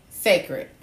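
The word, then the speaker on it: Cécred: They just launched Secret, and they spell it C-E-apostrophe-C-R-E-D, Secret.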